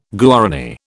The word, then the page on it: Guarani: British English